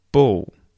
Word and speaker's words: bull: So that is with the L well pronounced, bull. But quite often across a lot of English dialects, not just Australian English, we will sort of mute the L and we don't pronounce it like a L, and it sounds more like a O, a W kind of sound. So bull, bull, as opposed to bull.